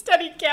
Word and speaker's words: Steadicam: Steed? – No, it's Steadicam. I don't know why I said Steadicam. – I was like, am I saying this wrong?